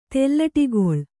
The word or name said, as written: ತೆಲ್ಲಟಿಗೊಳ್